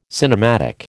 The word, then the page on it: cinematic: American English